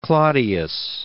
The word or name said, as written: Claudius